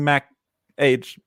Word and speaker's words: Mach: And then I think there was another follow up on the question earlier about the Mac. Age. Is it Mac Age? How do you pronounce it? M-A-C-H? – M-A-C-H. Oh, Mac.